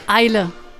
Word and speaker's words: Eile: Eile. – Made in Eile, so it's a combination of Deutsch and English.